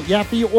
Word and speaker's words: Yaffee: Don't forget to like the Yaffe Facebook page. Follow me next at Yaffe or Instagram at Yaffe Live. And it is spelled Y-A-F-F-E-E.